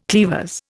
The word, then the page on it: cleavers: Indian